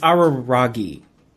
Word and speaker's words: Araragi: Well, Aramaki isn't given anything to do in the movie anyway. – It's Araragi.